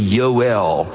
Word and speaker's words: Joel: In the Hebrew, it would not have been pronounced that way. There's no J in the Hebrew alphabet. So his name would be Yoel, emphasis on the L for Elohim.